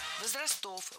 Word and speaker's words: возрастов: Возрастов, возрастам, все это неверно. Правильно, возрасты, возрастов, возрастам, возрастах. Во всех формах сохраняем ударение на первом слоге, как это делал Александр Сергеевич Пушкин, написавший недленные строки «Любви все возрасты покорны».